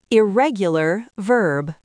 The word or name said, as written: irregular verb